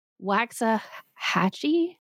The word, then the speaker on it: Waxahachie: Waxahachie, Texas, to become sharecroppers.